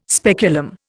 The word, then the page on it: speculum: Indian